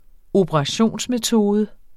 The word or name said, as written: operationsmetode